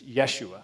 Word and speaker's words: Yeshua: So Joshua, if you pronounce that in Hebrew, it's Yeshua.